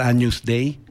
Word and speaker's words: Agnus Dei: se debe pronunciar Agnus Dei, el claro, Cordero de Dios, el expiatorio.